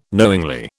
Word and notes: knowingly: British English

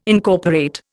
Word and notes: Incorporate: Indian

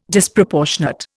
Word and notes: Disproportionate: Indian

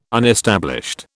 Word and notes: unestablished: British English

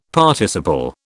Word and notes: Participle: British English

